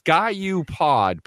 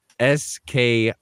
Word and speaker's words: SkiU Pod: Well, we got, once again, it's SkyU Pod, but it's spelled SKIU Pod. So, you know, you can tell your friends they're morons when they see this written out and they say, Ski U, because it is Sky U Pod.